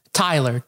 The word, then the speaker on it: Tyler: Tyler. T-Y-L-E-R. – Tyler, who? – My last name is Campbell. C-A-M-B-E-L.